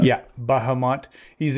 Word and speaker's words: Bahamut: And what I would like to submit is Balmont. Am I saying that right? Balmont? – Bahamut? So, Bahamut. There we go.